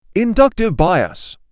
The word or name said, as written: Inductive bias